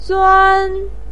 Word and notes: zuan: 1

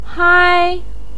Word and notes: hai: 1

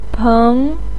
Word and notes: peng: 2

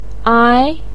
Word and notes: ai: ai2

